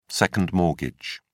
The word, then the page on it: second mortgage: British English